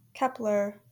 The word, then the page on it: Kep1er: / ˈ k ɛ p l ər /